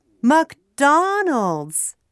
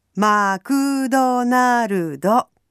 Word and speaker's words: McDonald's: It's McDonald's, not Makudonarudo. Let's practice English rhythm today. McDonald's. Please repeat after me. McDonald's. Okay, this time let's tap our desks. McDonald's.